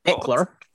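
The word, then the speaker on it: Ekeler: On stats, Eckler, no extra syllable in the middle, had 709 receiving yards.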